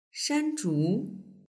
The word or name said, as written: shanzhu